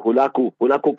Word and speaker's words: Hulagu: Some pronounce it as Hulagu or Hulaku Khan, the grandson of Genghis Khan.